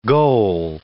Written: goal

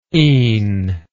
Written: een

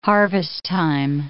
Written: harvest time

